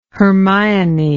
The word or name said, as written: hermione